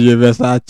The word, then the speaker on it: Versace: Regardez, prononciation sans rage, Versace. Moi, c'est un Versace.